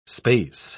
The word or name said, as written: space